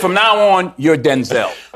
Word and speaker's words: Denzel: Because, wait a minute, because my name's not pronounced Denzel. My name's pronounced Denzel.